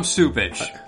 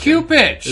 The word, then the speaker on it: Cupich: Well, that's how you're supposed to pronounce that name. I don't. It's not American. – It's Cupich. – It's what? – Oh, it's Cupich. – I know, but everybody says Supich. – Why? That's dumb. Why would you make that a soft C for people who haven't seen it spelled? It's C-U-P-I-C-H, which every time his name comes up, I hesitate to, because I'm like, I can't.